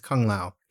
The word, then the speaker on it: Kung Lao: Is it Kung Lao or am I saying it wrong? – It's Kung Lao.